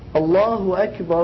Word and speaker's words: Allahu Akbar: So the way to say it is Allah, the correct way to say it is Allahu Akbar, Allahu Akbar.